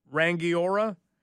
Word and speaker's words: Rangiora: Today, we go to New Zealand, and I believe we pronounce it Rangiora. If you're from New Zealand, help me out.